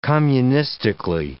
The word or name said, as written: communistically